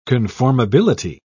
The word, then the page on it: conformability: British English